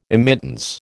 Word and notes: immittance: American English

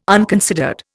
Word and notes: unconsidered: Indian

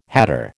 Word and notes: hatter: American English

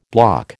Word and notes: block: American English

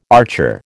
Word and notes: archer: American English

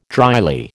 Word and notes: dryly: American English

